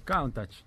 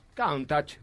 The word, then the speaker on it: Countach: Countach. Countach. Countach. – Countach. Lamborghini Countach. Countach. Es como si lo dijera el patrino. – Ah, eh, Lamborghini Countach.